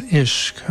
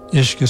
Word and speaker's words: Ishq: It comes to us via the sonic magic of Matt Hillier, an English musician based in far west Cornwall, and records as Ishq, among other monikers. Ishq is spelled I-S-H-Q.